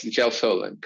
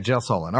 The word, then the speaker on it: gelsolin: Is gelsolin? Did I get that right? Or gelsolin? – Yes, gelsolin, correct. – Okay, yeah. Gelsolin.